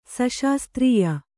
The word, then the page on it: ಸಶಾಸ್ತ್ರೀಯ: saśastrīya